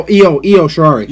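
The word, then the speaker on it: Io Shirai: It's not Bull Nakano. It's like Lo Shirari. I'm probably butchering the pronunciation of that. – Oh, Io Shirari.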